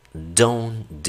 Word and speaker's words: Don't: Aunque veas que el don tiene hasta la T, ya no se pronuncia. Los nativos normalmente pronuncian hasta la N cuando lo hacen de manera natural.